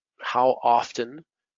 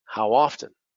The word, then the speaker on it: often: My goal with this is for people listening to realize how often we say how often. And you can pronounce it with the T or not with the T. So how often, fast, how often, is slower.